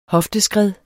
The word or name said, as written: hofteskred